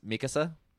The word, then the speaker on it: Mikasa: Officially in Japanese, Mikasa. But well, then that's the proper way to say it.